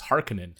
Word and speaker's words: Harkonnen: Not Harkonnen anymore. It's Harkonnen now. – Harkonnen, yeah. We've been saying it wrong our entire lives. – Yeah. So Harkonnen, the correct Finnish way to say it, I guess.